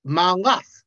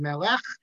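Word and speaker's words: Melech (מֶלֶךְ): So I rest my case that the way it should be pronounced is Melech, even though that's not grammatical, but that's how the poet intended it.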